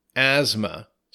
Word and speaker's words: asthma: That's A-S-T-H-M-A, asthma. So be careful. The T-H is silent in this, and it almost sounds like a Z a little bit, asthma.